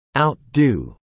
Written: outdo

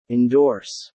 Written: endorse

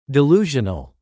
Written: delusional

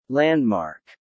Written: landmark

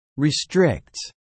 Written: restricts

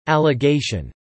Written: allegation

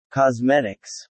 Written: Cosmetics

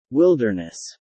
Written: wilderness

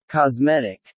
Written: cosmetic

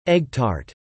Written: egg tart

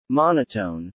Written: Monotone

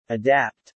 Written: adapt

